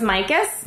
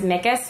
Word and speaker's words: Mickus: So it's Mickus. – Mickus. – But I have a bit. No. But I'm brought up on stage every single time as Mickus. So I have a bit about how it's M-I-C-K.